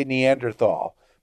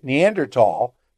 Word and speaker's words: Neanderthal: And here in the U.S., we say Neanderthal. But if you read a lot of scientific literature, you end up pronouncing it Neanderthal because that's how they spell it in Europe. At least in Britain, Australia, the journal.